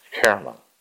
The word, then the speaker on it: Hermon: In Hebrew, it's pronounced Hermon.